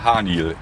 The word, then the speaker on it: Haniel: Ja, und wir sind heute Morgen an der Halde Haniel.